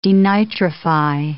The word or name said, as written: denitrify